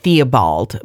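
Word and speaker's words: Tiobóid: It's pronounced Theobald, but it's spelled T-I-O-B-O with a little wiki thing, I-D.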